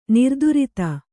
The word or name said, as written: ನಿರ್ದುರಿತ